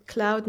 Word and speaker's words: Claude: Und deshalb haben sie gedacht, sie nennen ihn Cloud Number 9. Und deshalb spricht man den Namen so aus, obwohl er ja Claude geschrieben wird.